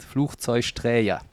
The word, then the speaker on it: Flugzeugträger: Aber das erste spricht sich wie CH, Fluch, dann Zeug, weil wir in Köln gerne Sch-Laute machen. Und Träger, weil auch vieles mit so einem J belegt wird. Flugzeugträger.